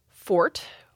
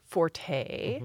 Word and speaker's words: forte: He said, I know it should be fort, but I talk to people and I think they may think it's supposed to be pronounced forte. So if I say fort, even though I know it's correct, they may think that I'm saying it wrong.